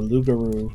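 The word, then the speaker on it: loup-garou: It's said that you can become a Rougarou or Lugaru, depending on your pronunciation, if you break certain religious norms.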